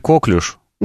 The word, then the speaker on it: коклюш: Ты сказала, что ли, коклюш? – Да. Коклюш, новая вакцина. – Естественно, появились люди, которые пишут, что правильно говорить коклюш.